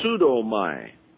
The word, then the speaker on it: pseudomai: The Greek verb to lie is pseudomai. It's like a P with an S, that funny looking, looks like a three-pronged fork there, the first word. It's pronounced psu. Pseudomai.